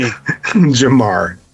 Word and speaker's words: Jahmyr: Jamar.